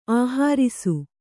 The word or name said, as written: ಆಹರಿಸು